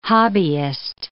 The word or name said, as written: hobbyist